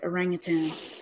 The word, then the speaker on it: orangutan: Orangutan, actually. That's how you're supposed to pronounce it, orangutan.